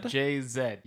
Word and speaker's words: Jay-Z: With the artist, I believe I'm pronouncing this right, Jay-Z? – Jay-Z, yes.